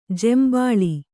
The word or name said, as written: ಜೇಂಬುಳು